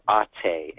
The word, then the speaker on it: ate: And I think I read somewhere that the Greek word is ate, A-T-E, long E.